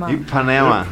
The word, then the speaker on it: Ipanema: Ipanema, eu falei certo, né? – Ah, é isso mesmo. – Ipanema.